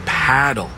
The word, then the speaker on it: padel: No, that's paddle. Rhymes with saddle.